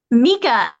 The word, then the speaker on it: Mika: Mika. M-I-K-A. – M-I-K-A is the first word.